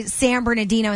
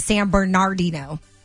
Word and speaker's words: San Bernardino: Yeah, I get called for that one, and I say San Bernardino instead of San Bernardino. The R. – Yeah, San Bernardino.